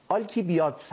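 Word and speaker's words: آلکیبیادس: این تلفظ صحیحش رو من نگاه کردم، آلکیبیادت هست.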